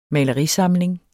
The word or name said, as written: malerisamling